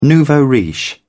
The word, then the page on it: nouveau-riche: British English